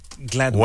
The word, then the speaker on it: Gladwell: Gladwell.